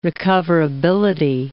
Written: recoverability

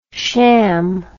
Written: cham

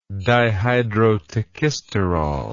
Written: dihydrotachysterol

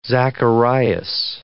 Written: Zacharias

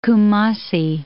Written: Kumasi

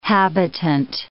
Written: habitant

